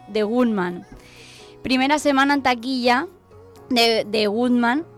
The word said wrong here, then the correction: Gunman: ¿se dice así bien, Jesús Adolfo? Gunman. Ah, perdona.